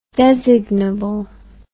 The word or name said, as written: designable